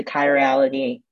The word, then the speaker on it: chirality: In the chirality. – You said it right. Yeah. And the chirality. You got it.